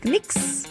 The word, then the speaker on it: Knicks: Rafa, tô atualizando aqui o nosso feed, a gente volta a falar de esporte, porque Knicks e Seleção Brasileira, eu falei certo? Knicks? – Falou certinho. – É assim que pronuncia? – Falou certinho, falou certinho.